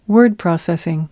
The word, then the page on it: word processing: American English